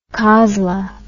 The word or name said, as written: cosla